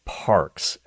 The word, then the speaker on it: PARX: Now, I don't think they're doing it for religious reasons, but the program is actually called PARKS, and that's spelled P-A-R-X, you know, with the small x. Get it? You know, the prescription sign, R-X, PARKS.